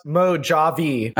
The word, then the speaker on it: Mojave: I believe it's pronounced Mojave.